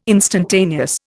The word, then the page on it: instantaneous: Indian